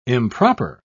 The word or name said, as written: improper